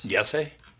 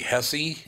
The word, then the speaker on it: Jesse: Yessie? – Well, they pronounce J's as Y's. So he would be Jesse Ventura. – What? – Yep. – Okay. So would he be Hesse in Mexico? – Spanish, yeah.